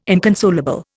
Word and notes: inconsolable: Indian

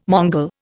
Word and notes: Mongol: Indian

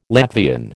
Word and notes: Latvian: American English

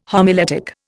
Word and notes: homiletic: Indian

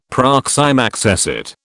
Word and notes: proxime accessit: British English

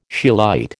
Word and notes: scheelite: American English

